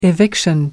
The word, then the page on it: eviction: American English